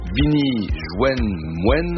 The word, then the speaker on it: Bini Jwen Mwen: Alors, faut le prononcer bien parce que c'est du créole. Bini Jwen Mwen, ça veut dire « Venez me rejoindre à la cantine » et sur France Inter, évidemment.